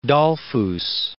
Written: Dollfuss

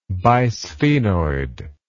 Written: bisphenoid

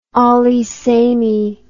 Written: allee samee

